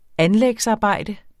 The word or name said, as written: anlægsarbejde